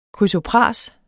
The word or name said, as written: krysopras